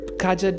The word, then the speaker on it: Kaja: Again, remember, it might be spelled Kaja, like K-A-J-A, but it's actually pronounced Kaja, because the last A is closed, and it becomes almost like an E.